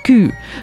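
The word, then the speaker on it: кю (九): В Китае и Японии несчастливыми считаются числа 4 и 9, поскольку 4 произносится по-японски как «щи», что созвучно слову «смерть», а 9 «кю» напоминает слово «боль».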